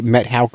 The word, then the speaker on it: Methow: So I'm at the Methow Creek. By the way, it's spelled M-E-T-H-O-W.